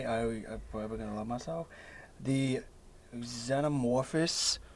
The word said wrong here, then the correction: Xenomorphs: The Xenomorphs? – Xenomorphs. – There we go.